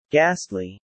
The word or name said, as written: ghastly